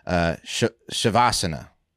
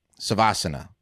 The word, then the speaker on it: Savasana: They have an incredible pose you do called the Shavasana. Shavasana. And it's the pronunciation of the Sanskrit word Savasana.